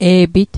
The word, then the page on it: e'pit: Mi'gmaq